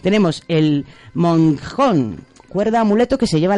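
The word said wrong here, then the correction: Mongkhon: El moncón.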